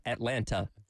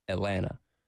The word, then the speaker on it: Atlanta: Same thing with Atlanta. It's not Atlanta. It's Atlanta. – There is a county, obviously, in South Carolina. – Yep. – H-O-R-R-Y.